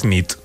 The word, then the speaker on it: nit: Y noche es nit. – Muy bien. – Buena nit. Buena nit, es verdad. – Buena nit.